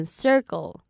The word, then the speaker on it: circle: 那接下来是 circle 这个字，要注意 ir 的卷舌音 sir。那 cle 的 k 可以念成类似 g 的音，念成 circle, circle。